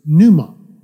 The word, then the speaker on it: pneuma: The word in Greek is pneuma. The P is silent. Say that with me.